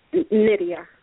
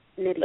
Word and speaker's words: Nydia: Nydia, with an N. Nydia. – Lydia. – M, how do you spell it for me? M-I-N-D-A? – Lydia with an N-Y-D-A.